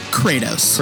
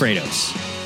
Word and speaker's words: Kratos: By the way, his name is Kratos. – Kratos. – with a long A.